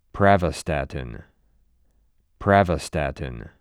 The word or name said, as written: Pravastatin